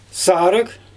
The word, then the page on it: saaryG: Xyzyl